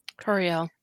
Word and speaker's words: Tauriel: So that's why Terrell that I said that wrong again. Tauriel.